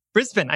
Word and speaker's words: Brisbane: Brisbane. – I said it right. I had to remind myself, don't say Brisbane.